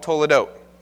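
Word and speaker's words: toledot (תולדות): It's called toledot. Okay? If you keep notes, write that down. I try to break it up so you would know how to pronounce it correctly. Toledot.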